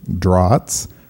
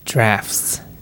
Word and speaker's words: Draughts: And hopefully we're saying that right, because we don't want another debacle like the Checkers episode where we recorded saying that it was called Draughts. When it's actually pronounced drafts.